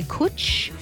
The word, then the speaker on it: cwtch: If you are Welsh, and you know how to pronounce the name of the Eastern Grey, because it's spelt C-W-T-C-H. They're saying that it's pronounced, it's either Kutch or Kutch. I'm going to say Kutch.